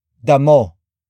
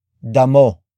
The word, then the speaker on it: damo: And that is damo. Damo. Damo. Damo is spelled as D-A-M-O.